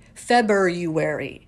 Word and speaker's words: February: And another good one is February, right? There's that little extra R in there. Like, it's kind of tucked in and hiding away. February.